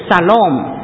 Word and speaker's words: shalom: 好，待会儿我们再读下面一节那平安这个字呢，这个是希伯来文的，这个字的发音呢是 shalom 我想大家很熟悉 shalom，我常常听到这个字牧师讲到的时候有时候就会引用 shalom 这个字。